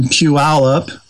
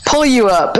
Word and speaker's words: Puyallup: I say that slowly so people who aren't from here can pronounce it correctly. – Pull you up. That's what it always looked like to me. But yeah, I had to have my son-in-law, Eric, had to teach me how to say it right.